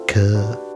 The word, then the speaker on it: care: It's an overcorrection on the word occurs, because in George's thicker Liverpool accent, care is often pronounced cur.